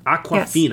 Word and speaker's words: Awkwafina: And it is starring an awesome all-female cast of Sandra Bullock, Kate Blanchett, Anne Hathaway, Mandy Kaling, Sarah Paulson, Awkwafina? Am I saying that right? – Yes, I think so. – Awkwafina, yes.